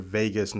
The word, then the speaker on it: vagus: called your vagus nerve, like Las Vegas. – Okay. – It's spelled V-A-G-U-S.